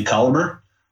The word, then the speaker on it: Colomer: You can find me on LinkedIn, Timothy Collamer. Last name is spelled C-O-L-O-M-E-R.